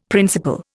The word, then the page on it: principle: Indian